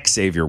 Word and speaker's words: Xavier: He kept calling him Xavier. And somebody in the comments said, no, his name is Xavier. It was a video you put out in college, like asked him, how is your name pronounced? And he's like, oh, it's not Xavier. It's Xavier.